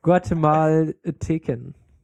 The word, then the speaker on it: Guatemalteken: Also hier, Guatemala heißt Guatemaleten. Nein, ich kann es nicht mehr aussprechen. Guatemalteken. – Ach so. – Malteken. – Malteken. – Ja, Guatemalteken.